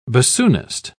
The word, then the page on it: bassoonist: British English